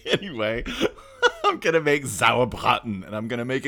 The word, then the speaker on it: Sauerbraten: Only if you learn to say it right. Sauerbraten. Listen, if you speak German, you have to imagine being very angry with a big sausage in your mouth.